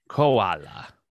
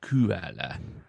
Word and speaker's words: koala: because you would need two O's to make the oo sound. – Exactly. – So it's koala, not koala.